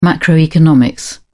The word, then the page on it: macroeconomics: British English